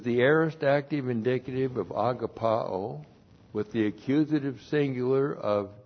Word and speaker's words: agapao: agapao. A-G-A-P-A, long O, agapao.